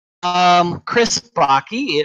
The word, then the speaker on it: Bakke: Chris Bakke, it rhymes with hockey and Rocky.